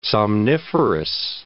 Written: somniferous

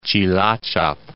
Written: Tjilatjap

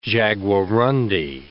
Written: jaguarundi

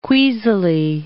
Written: queasily

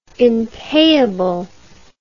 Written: impayable

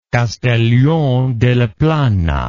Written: Castellón de la Plana

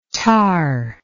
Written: thar

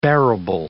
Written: bearable

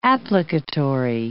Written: applicatory

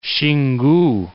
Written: Xingu

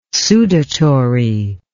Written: sudatory

